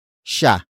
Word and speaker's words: siya: The next word is sha. Sha. Sha. Sha is spelled as S-I-Y-A.